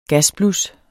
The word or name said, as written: gasblus